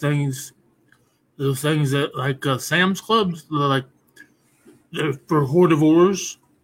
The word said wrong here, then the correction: hors d'oeuvres: You mean hors d'oeuvres? – Oh, is that how you say it? I always thought it was. Because it's spelled H-O-R-D. – Hors d'oeuvres. – Hortivores, okay. – It's hors d'oeuvres. – Hors d'oeuvres. – You don't pronounce the H.